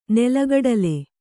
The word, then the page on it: ನೆಲಕಡಲೆ: nela kaḍale